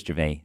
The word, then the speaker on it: Gervais: Chris Gervais, sorry, silent S.